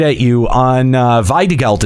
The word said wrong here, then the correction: Wiedergeld: It's supposed to be Wiedergeld. – Wiedergeld. – Okay. All right. I was in the ballpark. But there's supposed to be an R in there.